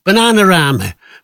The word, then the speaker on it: Bananarama: Bananarama, or you give it the correct Scouse pronunciation, Bananarama. Bananarama, let's take that, quite a few hits.